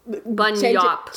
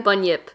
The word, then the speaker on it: bunyip: Bunyap? – Very close. Change that vowel in the second syllable. – Bunyip. Bunyop. – Change it again. – Bunyip. – There it is.